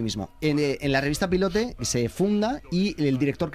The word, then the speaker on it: Pilote: Hasta que en 1959 eh, se funda la revista Pilote, la revista Pilote, que seguro que no se dice así. pilote Pilot. Eso es pues pero se decía pilote.